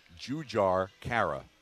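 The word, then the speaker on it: Jujhar Khaira: Now, real quick, while Michael mentioned the Rangers, what did you think of Trouba's hit on the Blackhawks center, Juhar Kyra? I got it. I think I nailed Kara. – It. No need to correct. Right. I'm just kidding. How do you say it? – Jujhar Kyra.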